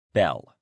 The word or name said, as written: bell